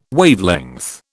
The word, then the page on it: Wavelength: British English